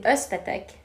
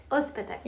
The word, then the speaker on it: Özpetek: Özpetek. – Özpetek, yes.